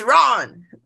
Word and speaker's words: Iran: So the Iranians got mad because the American guy pronounced the country name Iran wrong. He said Iran, Iran. He said Iran. And the little guy got mad and was like, it's Iran.